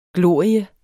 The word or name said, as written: glorie